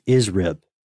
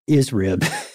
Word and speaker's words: ISRIB: Maybe it's pronounced ISRIB, you know, like McRib, but yeah, for your brain.